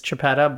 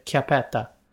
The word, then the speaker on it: Chiappetta: Well, the English way to pronounce it is Chiappetta, but I'm Italian, so we pronounce it Chiappetta.